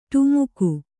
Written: ಡಿಮಕಿ

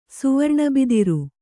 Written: ಸುವರ್ಣಬಿದಿರು

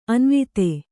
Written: ಅನುವಾದ

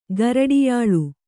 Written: ಗರಡಿಯಾಳು

